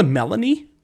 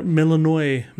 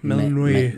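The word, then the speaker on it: Melinoë: Her name's I'm gonna call her Melanie. It's not pre- it's not spelled like that. M E L I N O E with a little blip on top of it. Mel- Melanoy. – Melanoy.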